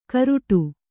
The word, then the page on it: ಕರುಟು: karuṭu